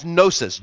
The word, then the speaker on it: gnosis: That is gnosis, G-N-O-S-I-S, and the G is silent, okay?